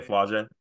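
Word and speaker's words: Flau'jae: And is it Flauge? Is that how you say it? – Yeah, Flauge.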